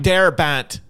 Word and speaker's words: Frank Darabont: Frank Darabont.